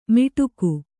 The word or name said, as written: ಮಿಡುಕು